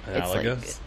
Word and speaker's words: analogous: I can't say that word, so I'm not going to try, but like, analogous? – Like, is that how you say it? – Yes. – Because I didn't know, because like analogy, so like analogous.